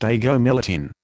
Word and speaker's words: agomelatine: Active ingredient, agomelatine, pronounced agomelatine. This leaflet provides important information about using Valdoxan.